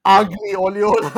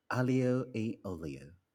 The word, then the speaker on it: aglio e olio: It's aglio e olio. Ugly olio. – Let me try that again. Aglio e olio. – That's not too bad. Okay, better than aglio olio.